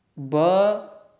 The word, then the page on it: b: sanskrit